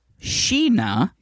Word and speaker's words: Sheena: My name is Sheena. – Hi, Sheena. – Pronounced Sheena.